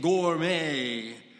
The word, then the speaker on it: Gourmet: It's spelled with a T, but you say it, Gourmet.